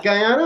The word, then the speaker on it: Guyana: The two most notable are the Bakken and Guyana. Am I saying that correctly? – Yeah, Guyana. I think that's absolutely, you know, fine.